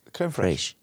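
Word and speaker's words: crème fraîche: creme, I think you have to say it like that how do you say this word? F- fresh, frachi? Creme fraiche. Creme fraiche. They go together.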